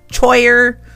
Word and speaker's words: choir: C-H-O-I-R, choir. The boys' choir performs tonight, choir. They have a very big choir. It looks like the C-H should be pronounced choir, but it's choir.